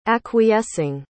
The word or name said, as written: acquiescing